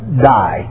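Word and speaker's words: die (δεῖ): It's spelled, transliterated, D-E-I. You know, and it's pronounced, it would be pronounced in the English tongue just like die, D-I-E.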